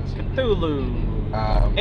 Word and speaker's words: Cthulhu: Lulu Cthulhu, I think it was. – Cthulhu. It rhymes. Come on.